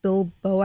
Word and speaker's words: biloba: He was starting her on ginseng and ginkgo biloba.